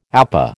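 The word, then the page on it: épée: American English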